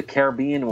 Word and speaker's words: Caribbean: And I just want other podcasters to know that that is how you pronounce it. It is not Caribbean. I've had very famous podcasters, very famous, laugh at people for saying Caribbean.